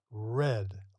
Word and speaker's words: read: Notice here that the word read, or the base verb read, in the simple past and the past participle forms are pronounced red, like the color red, red.